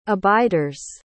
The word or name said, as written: abiders